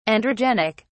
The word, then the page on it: androgenic: British English